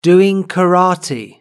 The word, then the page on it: doing karate: British English